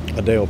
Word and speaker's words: Adele: Adele Pierce. – Adele, A-D? – A-L. Pronounced like the singer.